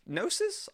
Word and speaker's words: Gnosis: It is called, I really hope I pronounce this properly, Serpent of Gnosis? I want to say that's a silent G. G-N-O-S-I-S.